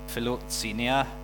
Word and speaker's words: philoxenia: Yeah, philoxenia.